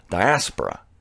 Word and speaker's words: diaspora: It's not diaspora. Don't be caught dead saying diaspora. It's diaspora. Diaspora. You put the accent on the second syllable.